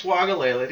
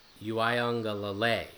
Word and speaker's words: Uiagalelei: You would think it's Wagalele to Clemson, but I'll give you a hint, it's not. – And that's not even his name, so. – Pronounce it for me. – Uiagalelei. – That's ridiculous. Uiagalelei? Where's the U? There's the first U, but where's the second U? – I don't know.